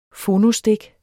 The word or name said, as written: phonostik